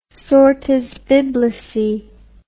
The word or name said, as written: sortes biblicae